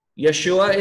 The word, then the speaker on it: Yeshua: Yeshua is the Hebrew way of pronouncing it.